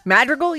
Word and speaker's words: Madrigal: Madrigal? You said that right.